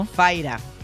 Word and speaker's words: Fyra: ¿Cómo se pronuncia, esto? – Faira. – Faira. – Faira. F-Y-R-A.